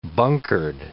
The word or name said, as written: bunkered